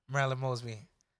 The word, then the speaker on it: Marilyn Mosby: We have State's Attorney Marilyn Mosby.